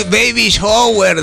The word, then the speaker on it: baby shower: No, así, baby shower, se dice, Colocho.